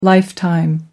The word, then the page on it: lifetime: American English